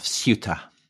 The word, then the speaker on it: Ceuta: and specifically to the great fort of Ceuta.